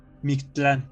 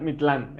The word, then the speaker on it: Mictlán: Ya, pues llegan a, al Mictlán. Siempre se me complica pronunciar esta C antes de la T. Mictlán. Sí, porque siempre es Mictlán, el Mictlán, madre.